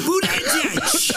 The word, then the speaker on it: Buttigieg: I kind of miss when Donald Trump was trying to say Buttigieg's last name. – Buttigieg. Say edge like the edge of a building. Edge. Buttigieg.